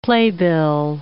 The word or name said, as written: playbill